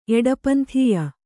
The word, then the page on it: ಎಡಪಂಥೀಯ: eḍapanthīya